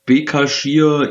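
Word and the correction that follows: Başakşehir: Istanbul oder so, der Erdogan-Verein. – Genau, Basaksehir, ja.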